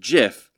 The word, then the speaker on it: GIF: But he was just out there saying, like, it should be pronounced GIF, like the peanut butter, mm-hmm. rather than a hard G.